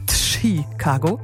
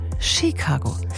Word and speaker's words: Chicago: Chicago heißt, sondern Chicago. Ein kleiner, aber feiner Unterschied. Also nicht mit einem T vorne ausgesprochen, Chicago, sondern Chicago.